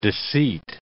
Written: deceit

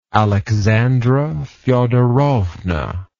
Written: aleksandra fyodorovna

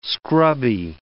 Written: scrubby

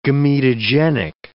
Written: gametogenic

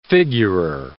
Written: figurer

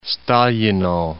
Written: Stalino

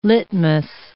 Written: litmus

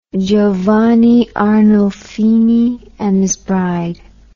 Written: giovanni arnolfini and his bride

